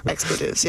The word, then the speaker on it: expletives: And then, like, they're playing let me pronounce it, explicatives? – Expletives, yeah. – Expletives.